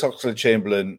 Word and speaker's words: Oxlade-Chamberlain: If you're Alex Oxlade-Chamberlain...